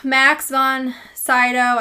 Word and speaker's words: Max von Sydow: So, Max von Sydow, I hope I'm saying his name right. – Sounds right.